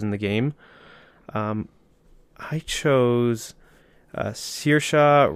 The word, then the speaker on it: Saoirse: And I think Saoirse could definitely play that role pretty well.